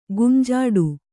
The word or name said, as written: ಗುಂಜಾಡು